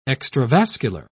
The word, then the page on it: extravascular: British English